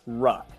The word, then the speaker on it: Ruch: So it's pronounced Ruck. So it's as if there is a K instead of an H.